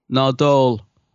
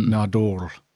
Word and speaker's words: Nardole: Although how does Capaldi say it? He says it different, doesn't he? – Nardole. – I can't remember. He said it in the episode. I was like, oh, is that how you say it? – Yeah. Almost sounds like a Lord of the Rings character. Nardole.